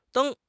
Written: deng1

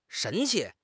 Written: 神气

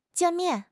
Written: jian4 mian4

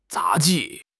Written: 杂技

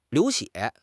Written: liu2 xue4

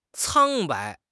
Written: 苍白

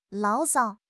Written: 牢骚

